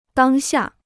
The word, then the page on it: 当下: dāng xià